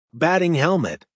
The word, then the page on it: batting helmet: American English